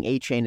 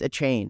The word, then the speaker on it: Achane: He came out and like got mad at people because they were mispronounced. They kept saying Achane. It's Achane.